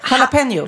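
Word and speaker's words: jalapeño: Jalapeno. – Jalapeno, exakt. Men många säger jalapeno. Jalapeno. Uh-huh. Och det är fel. För det uttalas, det där j-et hörs ju inte.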